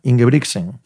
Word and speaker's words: Ingebrigtsen: Ingebrigtsen. ¿Lo he dicho bien? – Sí. – Bueno, poco regular. – Poco regular. – No hace falta que me mires con... antes de remarcar la G delante de la T.